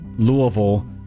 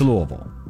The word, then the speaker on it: Louisville: There are some others who are throwing shade at the way they pronounced Louisville being pronounced this way. – Louisville? – Well, that's how all the out-of-towners say it. I don't care. I think it's great. – They don't live here. The only people that do, the ones that say it like that live here. – Live here or Southern Indiana. – Exactly. – Listen, if you're south of Indianapolis, you probably should know it's Louisville.